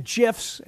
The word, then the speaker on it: GIF: Do you like do you like looking uh, at, at GIFs, and yes, GIF is the correct pronunciation, of multiple pitches coming out of a pitcher's hand at the same time.